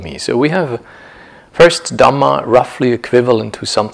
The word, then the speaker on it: Dhamma: That's probably the most liberal, the most broad way of understanding the word Dhamma here with a long A, meaning not the Dhamma of the Buddha, but the many Dhammas that constitute our experience.